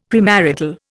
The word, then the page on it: premarital: Indian